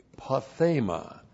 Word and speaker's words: pathema: P-A-T-H, long E-M-A, pathema.